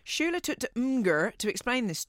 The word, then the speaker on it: Imgur: Celeste is in Colorado, but Shula took to Imgur. Am I saying that right? Imgur. – That's how I say it, but I also said like Mimi for a while.